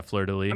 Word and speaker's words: fleur-de-lis: Yeah, fleur-de-lis.